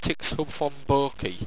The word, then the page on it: tixhubphonborky: owstwecian